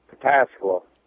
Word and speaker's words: Pataskala: Pataskala? – Pataskala.